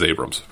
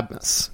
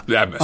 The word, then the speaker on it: Abmas: Yeah, uh, it, we are so far back in Max Abmas, we didn't pronounce his name right. That's how long we've been at this party. We thought it was Abrams, but yeah, right, uh, yeah, we thought it was a- Abmus.